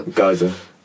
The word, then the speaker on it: geyser: Geyser. – Yeah, thank you. – I think it's pronounced geyser. G-E-Y-S-E-R. – Yeah, it's a geyser.